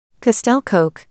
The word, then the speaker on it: Castell Coch: Castell Coch,